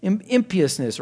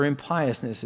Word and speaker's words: impiousness: Also trespass, going against a boundary, transgression, fault, falling aside, moral aberration, defeat, discomfiture, impiousness or impiousness, as you might pronounce it, and disharmony, a metaphor from music.